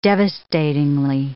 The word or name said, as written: devastatingly